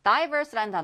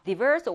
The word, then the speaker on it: diverse: Diverse라는 단어가 뒤에 나오는데요. Diverse or diverse. 둘다 발음 괜찮습니다.